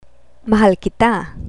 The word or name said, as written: mahal kita